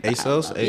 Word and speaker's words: ASOS: I love ASOS. I don't know if I said that right. But ASOS? – Yeah, I love that one.